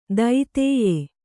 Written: ದೈತೇಯೆ

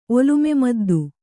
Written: ಒಲುಮೆಮದ್ದು

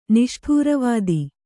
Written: ನಿಷ್ಠೂರವಾದಿ

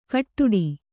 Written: ಗೊಡ್ಡಿ

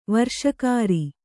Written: ವಿರಸಕರ

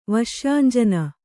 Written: ವಶ್ಯಾಂಜನ

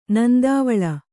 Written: ನಂದಿವಾಳ

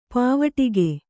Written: ಪಾವಟಿಕೆ

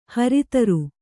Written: ಹರ್ತಾರ

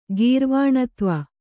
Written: ಗೀರ್ವಾಣತ್ವ

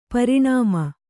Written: ಪೋರಾಣ್ಮ

